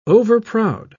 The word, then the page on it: overproud: British English